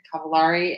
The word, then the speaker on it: Cavallari: Kristen Cavallari. I was mispronouncing it. Cavallari and Jay Cutler, I guess, have also decided to get a divorce.